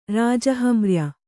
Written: ರಾಜಹಮ್ರ್ಯ

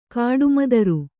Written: ಕಾಡುಮದರು

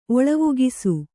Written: ಒಳವುಗಿಸು

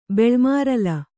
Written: ಬೆಳ್ಮಾರಲ

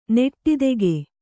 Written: ನೆಟ್ಟಿದೆಗೆ

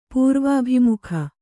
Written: ಪೂರ್ವಾಭಿಮುಖ

